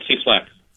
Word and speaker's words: Cieslak: Right, Cieslak.